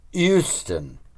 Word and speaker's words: Houston: Don't know if it's typical down there, but the Brissies pronounce it Houston with a silent H.